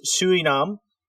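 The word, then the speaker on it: Sonam: Sanam, pronounced Suinam, means with merit, and Wangdu means spiritual power.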